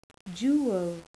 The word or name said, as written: jewel